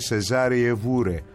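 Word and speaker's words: Cesária Évora: Λοιπόν, 27 Αυγούστου του 1941 έρχεται στη ζωή η Σεζάρια Εβόρα, που μου είπανε ότι στα πορτογαλικά η σωστή λέει προφορά, αν θες να το πεις, είναι Σεζάρια Εβούρε.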